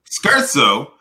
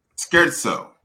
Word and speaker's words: scherzo: Okay, so Scherzo. And a vigorous, light, or playful composition, typically comprising of movement in a symphony or a sonata. And it's pronounced scherzo. Scherzo. Okay, cool.